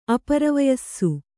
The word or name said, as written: ಅಪರವಯಸ್ಸು